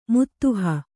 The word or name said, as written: ಮಿದಿಹ